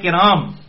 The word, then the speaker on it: کرام: بعض اوقات آپ بھی غلط لفظ بولتے ہیں اور علماء کرام، اچھا میرے منہ سے اکثر علماء کرائم نکل جاتا ہے، میں نے سوچا ہے علماء کرام یعنی عزت والے علماء آپ کی غلطیاں نکالتے ہیں.